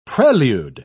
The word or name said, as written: prelude